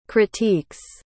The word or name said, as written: critiques